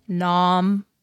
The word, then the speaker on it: Nom: And I feel like I always say his name wrong. I always say Nam. – Nam. Oh, you can say it that way. – Nom.